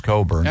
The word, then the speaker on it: Cockburn: It is spelled Cockburn, but right. it's pronounced Cockburn.